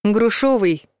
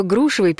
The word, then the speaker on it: грушевый: Некоторые считают, что грушевый пишется через «ё», и поэтому ударение падает на второй слог. Но никакой буквы «ё» там и близко нет. Правильно будет грушевый.